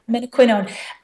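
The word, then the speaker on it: menaquinone: And how do you pronounce its scientific name, minoquinone? – Minoquinone. – Okay. – Yeah, minoquinone.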